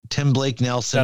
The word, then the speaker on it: Tim Blake Nelson: Well, Tim Blake Nelson. Am I saying it right? Tim Blake Nelson.